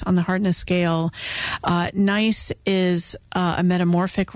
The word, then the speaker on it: gneiss: It is called There's Some Nice, as in G-N-E-I-S-S, Gneiss, Schist, and it's spelled S-C-H-I-S-T, and then in parentheses, don't take it for granted, instead of granted.